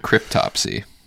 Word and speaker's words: Cryptopsy: Oh, is that how you say it? Cryptopsy? – Yeah. What did you think it was? – I don't know what I thought. That doesn't sound right, though. – Cryptopsy. I mean... – Cryptopsy, because there's a P in it. – Cryptopsy. – It's like cryptopsy. Autopsy from a crypt.